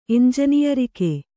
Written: ಎಂಜನಿಯರಿಕೆ

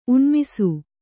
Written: ಉಣ್ಮಿಸು

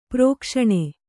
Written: ಪ್ರಕಾಶನ

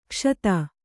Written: ಕುಶದ

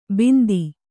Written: ಬಿಂದಿ

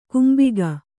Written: ಕಂಬಿಗ

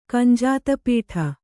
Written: ಕಂಜಾತಪೀಠ

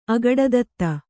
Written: ಅಗಡದತ್ತ

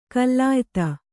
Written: ಕುಲಯುತ

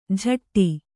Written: ಝಟ್ಟಿ

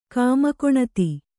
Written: ಕಾಮಕೊಣತಿ

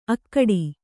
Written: ಅಖೋಟ

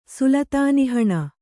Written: ಸುಲತಾನಿಹಣ

